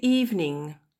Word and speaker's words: evening: in the evening. Le E, le deuxième E dans evening ne se prononce pas, on dit pas evening.